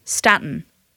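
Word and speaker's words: Staten Island: Like Stratton Island. – Like Stratton Island. – Yeah. – How do you say it? – Stratton. There's no R.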